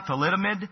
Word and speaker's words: thalidomide: Forgive me if I don't pronounce this right, but I think it's thalidomide. Is that right? Thalidomide? But it, what's that? Yeah, thalidomide.